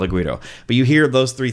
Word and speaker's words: güiro: No, güiro. Like it's a Spanish word. It's G-U-I-R-O.